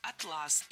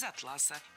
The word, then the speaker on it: атлас: Перед нами классические омографы. То есть слова, которые одинаково пишутся, но по-разному произносятся. Вот и атлас, и атлас пишутся одинаково, но слова-то это разные, с разным значением. И различить их можно только в устной речи с помощью ударения.